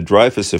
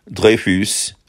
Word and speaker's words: Dreyfus: So in many ways, the Dreyfus Affair helped give birth to the creation of the modern Jewish state. Last but maybe not least is the fact that Dreyfus is the French pronunciation of Dreyfus, which means 3 feet.